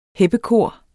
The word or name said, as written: heppekor